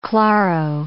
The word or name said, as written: claro